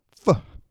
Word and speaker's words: Faux: Faux.